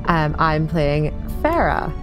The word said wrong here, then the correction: Faria: who I'm probably going to get their name wrong. I do apologise. – Yeah, it's Faria.